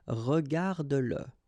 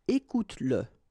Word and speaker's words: regarde-le: Remember that if you've got, in some situations, the pronoun le, and if it comes after your verb, like in that case, then you will have to pronounce it. Okay, so regarde-le, regarde-le, all right, regarde-le, écoute-le,